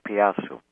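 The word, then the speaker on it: Piasa: And this article is on American and Japanese dragons and the correlation between the two different countries and this artwork, the Piazzu of Alton, Illinois. And if I didn't pronounce that correctly, please advise me. – Yeah, you did. That's correct. The Piazzu.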